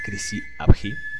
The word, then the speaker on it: Chrysi Avgi: Al principio del podcast lo pronuncié mal, pero según tengo entendido, así se pronuncia, Crisi-Abji.